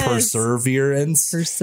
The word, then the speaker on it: perseverance: The first thing I learned about perseverance is that it's spelled P-E-R-S-E-V and there's not an extra R, right? – Like perseverance, which for whatever reason I thought there was.